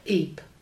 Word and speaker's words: Ypres: I'll spell it. Y-P... – Ypres. – Yeah, that's it.